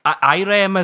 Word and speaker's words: Irem: Irem, is it? – Yeah. – Pronounced IREM.